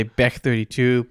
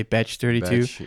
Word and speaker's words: Bech32: Yeah, I, th- I would say Bech 32, but others say Batch 32. yeah, B E C H 32.